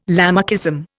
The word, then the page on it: Lamarckism: Indian